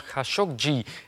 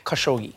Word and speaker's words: Khashoggi: Dus misschien even oefenen. – Jamal Khashoggi. Khashoggi. Khashoggi. Khashoggi. Khashoggi. Khashoggi. Jamal Khashoggi. – zo is het.